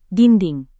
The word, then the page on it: dinding: indonesian